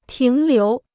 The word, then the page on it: 停留: tíng liú